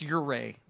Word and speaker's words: Ouray: And when Mindy and I were out west, we were in a town called Ouray. Beautiful town. I'm sorry? – Can you spell that? – No, I cannot. It starts with an O, and then it's Ouray. So there you go. The O silent.